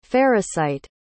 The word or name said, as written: farrisite